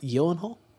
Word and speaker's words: Gyllenhaal: It's actually pronounced Gyllenhaal. – No.